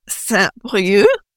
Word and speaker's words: Saint-Brieuc: So I started at a town city called Saint-Brieuc. It has a C at the end, but you don't pronounce the C.